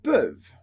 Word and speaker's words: peuvent: Sempre terminando em ENT, que a gente não pronuncia. Então, ils peuvent ou elles peuvent, terminando no som do V.